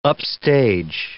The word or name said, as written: upstage